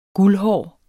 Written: guldhåret

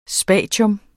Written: SPATIUMMERNES

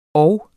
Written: og

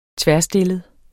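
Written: tværstillet

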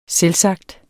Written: selvsagt

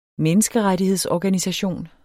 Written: menneskerettighedsorganisation

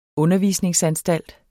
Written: undervisningsanstalt

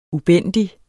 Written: ubændig

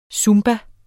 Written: zumba

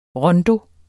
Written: RONDOERNE

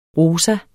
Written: rosa

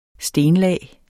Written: stenlag

